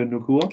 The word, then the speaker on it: Nacua: Uh, Nacua, Puka Nacua. No, Puka Nacua.